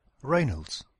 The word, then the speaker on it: Reynolds: Reynolds was born in Plympton. Ici, il fallait noter l'accent sur la première syllabe de Reynolds, juste avant le R, le mot born, juste avant le B, et la première syllabe de Plympton, juste avant le premier P.